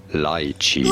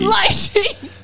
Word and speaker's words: lychee: No, it is not lychee, but rather lychee. – Lychee!